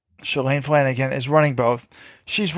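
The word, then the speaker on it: Shalane: Shailene Flanagan. And she's... – Who? – I don't know how to say her name. Shailene Flanagan. Thank you. It's Elaine, like L-A-N-E.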